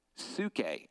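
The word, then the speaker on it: psuche (ψυχή): Now in the New Testament, this word soul appears 98 times and it's pronounced psuche. It's where we get our English word for psyche.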